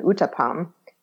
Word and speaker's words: uttapam: But there's also a smaller thick dosa called an uttapam, which is spelled U-T-T-A-P-A-M.